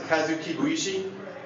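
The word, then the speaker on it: Kazu Kibuishi: next to Klaus is Kazuki Buishi, who you may know from the Amulet series of books and the Flight anthologies. – Did you pronounce that at all right? – Yeah.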